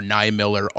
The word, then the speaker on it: Niemiller: I could tell people Niemiller, and I would get Niemiller or Niemiller all the time until I came up with body part, the beer, and then no one forgets it.